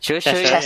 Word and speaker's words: Cheshire: This is the Evolution Studios that became Godmasters Cheshire, or how do you pronounce it? Cheshire. So, Cheshire.